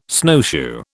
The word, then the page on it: snowshoe: British English